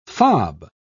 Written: fob

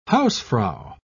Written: hausfrau

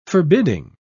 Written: forbidding

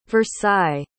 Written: versailles

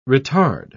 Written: retard

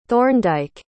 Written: thorndyke

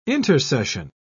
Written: intersession

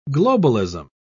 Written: globalism

